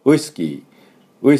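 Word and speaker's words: whisky: これすぼませないと、ウイスキー、ウイスキー、ちょっと違いますよね。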